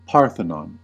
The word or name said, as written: Parthenon